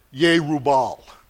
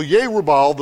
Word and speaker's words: Yerubal: I just try to pronounce it in the way the Hebrew language is pronounced, like Yerubal. There's no J sound, so that's how we would properly pronounce that name.